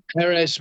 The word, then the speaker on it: Perez: how would you pronounce that surname? – Yeah, Perez, mate. I would say when you, Eric Perez, mate.